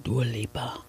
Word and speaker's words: Dua Lipa: Dua Lipa? – Dua Lipa.